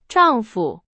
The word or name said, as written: 丈夫